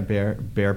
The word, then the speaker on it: Behr: I don't know how to pronounce it. It's like B-E-H-R or something. Yeah, Bear Paint.